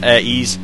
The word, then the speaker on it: Air 3S: Late to the party, Silver Sonic says its name is pronounced Air Ease. Sleet speak.